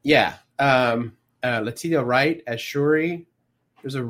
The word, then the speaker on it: Letitia: Speaking of pronunciations, I think it's Letitia. – It is Letitia. I messed it up.